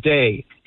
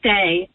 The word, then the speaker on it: Dei: I think Julia's been telling me, I think it's pronounced Day. – It's Day. – Oh, okay. – The font is a little funny, but that's a D.